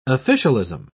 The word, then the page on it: officialism: British English